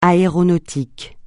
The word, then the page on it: aéronautique: francais